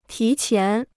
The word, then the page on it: 提前: tí qián